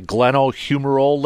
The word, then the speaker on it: glenohumeral: But he apparently had surgery for, is it a glenohumeral ligament and a capsule on his throwing mm-hmm.